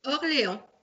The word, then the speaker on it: Orléans: So it's like Orleans, but how do you say where you're from? – Orléans.